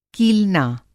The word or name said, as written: कीलना